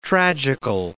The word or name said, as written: tragical